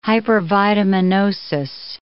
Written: hypervitaminosis

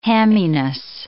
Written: hamminess

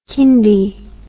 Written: kindy

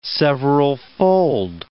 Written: severalfold

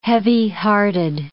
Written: heavyhearted

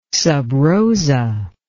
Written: sub rosa

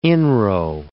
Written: inro

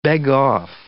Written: beg-off

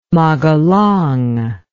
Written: Magelang

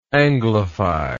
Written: anglify

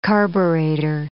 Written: carburetor

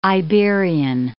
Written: Iberian

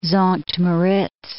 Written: Sankt Moritz

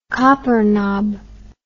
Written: coppernob

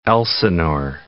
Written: Lake Elsinore

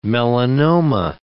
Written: melanoma

